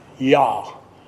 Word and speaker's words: Jah: I have trouble with that word, but it's like Rastarians, R-A-S. Starts Rastarians. They believe in Yah, J-A-H, and you pronounce the J, of course, as a Y.